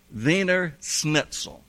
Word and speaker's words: Wiener Schnitzel: And we were told that we must not leave that country until we have eaten some Wiener Schnitzel.